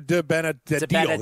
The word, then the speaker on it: DiBenedetto: How do I pronounce that there? – DiBenedetto. – That's right.